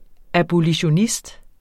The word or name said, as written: abolitionist